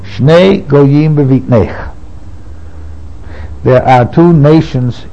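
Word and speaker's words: Goyim (גוים): Excuse me, it's pronounced Goyim, nations, but it's written Geim.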